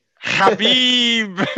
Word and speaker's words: Khabib: Khabib, that's it.